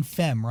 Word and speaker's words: Phem: I know that, so Shut Up is the one that has Travis on it, and then Femme, right? – Yeah. – I say that right? – You did. – P-H-E-M.